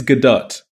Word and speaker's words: Gadot: It's Gadot.